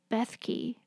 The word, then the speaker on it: Bethke: And yeah, you know, and Jeff Bethke, is that how you pronounce his last name? – That's how I pronounce his last name.